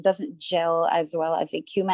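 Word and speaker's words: Eucheuma: One is ecuma, that's how you say it. – Yeah. – And I just gave it a Creole pronunciation for my brand, ecuma, because that's how we say it here.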